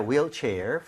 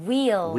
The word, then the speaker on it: wheelchair: 발음할 때 휠이 아니라 뭐라 그랬죠? – Wheel. – Wheel, right? 우리가 흰색도 화이트라고 안 하죠? – White. – White이라고 하죠. 그래서 H가 없는 느낌으로 발음할 때 wheelchair이라고 하는 게 맞습니다.